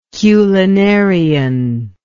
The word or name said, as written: culinarian